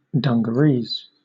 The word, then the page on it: dungarees: British English